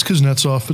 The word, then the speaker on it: Kuznetsov: I can't say his name. Help me out. It's Kuznetsov. Let's call him Kuzi.